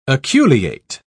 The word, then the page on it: aculeate: British English